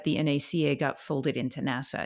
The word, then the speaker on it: NASA: I don't know, and I don't think anyone knows why NACA is pronounced with the letters and NASA is pronounced as a word.